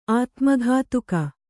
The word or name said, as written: ಅತಿಮುಕ್ತಕ